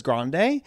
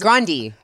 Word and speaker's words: Grande: So I changed the pronunciation of our last name. That's why everyone says Grande. – It's Grandi. – It was Grandi. – Grandi. – Yeah, I was raised Frankie Grandi.